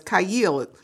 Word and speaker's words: chayil: And um it's defined uh, in the Strong's Concordance as Kayil. You spell it C H A Y I L